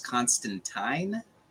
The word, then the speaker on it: Constantine: Now, the one thing I want to say is I've read tweets that it is Constantine. – Oh, yeah, that's how they say it.